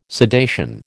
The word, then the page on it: sedation: American English